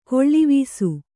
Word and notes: ಕೀಳುವಾಸಿ: Kannada

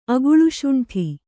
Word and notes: ಅಗಳುಶುಂಠಿ: agaḷuśuṇṭhi

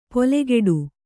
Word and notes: ಪೊಲೆಗೆಡು: polegeḍu

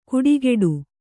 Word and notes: ಕುಡಿಗೆಡು: kuḍigeḍu

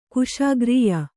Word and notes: ಕುಶಾಗ್ರೀಯ: Kannada

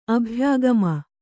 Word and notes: ಅಭ್ಯಾಗಮ: abhyāgama

